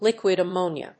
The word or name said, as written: liquid ammonia